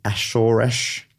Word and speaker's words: Azores: The species is found off the coasts of Europe, Africa, the Ashoresh. I had to look up the pronunciation. I'm sure that's all right. It looks like it says the Azores, but the pronunciation I looked up said Ashoresh. Anyway, and the Canary Islands from Norway to Angola and in the Mediterranean and Black Seas.